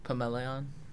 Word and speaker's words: Pamaylaon: How is it pronounced? – Pamaylaon.